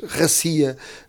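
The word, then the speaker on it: Racia (Россия): As pessoas iam no metro, em todo lado, gritavam Racia, Racia, quer dizer Rússia, de facto a pronúncia em russo diz Racia.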